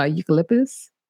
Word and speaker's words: eucalyptus: So I use eucalyptus.